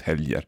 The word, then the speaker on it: helger: On the weekends, do you ever say helger with a hard G? – No. – Okay, so always helger.